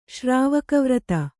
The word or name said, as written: ಶ್ರಾವಕವ್ರತ